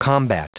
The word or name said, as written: combat